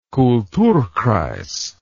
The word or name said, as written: kulturkreis